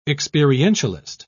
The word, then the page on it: experientialist: British English